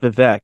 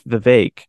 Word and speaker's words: Vivek: It's not Vivek, it's Vivek. I found that out the other day. Rhymes with fake, oddly enough.